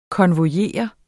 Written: konvojere

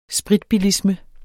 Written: spritbilisme

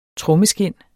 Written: trommeskind

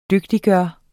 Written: DYGTIGGJORT

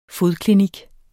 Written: fodklinik

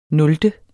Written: nulte

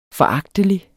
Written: foragtelig

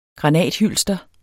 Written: granathylster

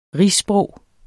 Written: rigssprog

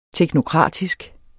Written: teknokratisk

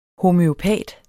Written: homøopat

